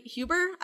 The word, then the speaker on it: Huber: A member of one of the protesters, whose name was Anthony Huber. Is that how that last name is pronounced? – Okay. I believe so. I can't say for sure, but yeah.